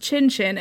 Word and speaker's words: Tintin: Tintin is called Tantan in Japanese because Tintin is pronounced Chin-Chin and means penis.